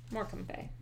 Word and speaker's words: Morecambe Bay: Oh, I did it right. Morecambe Bay. Yep. I knew that pronunciation because when I watched The British Office many times, he talked about Morecambe and Wise, and that's how he pronounced it.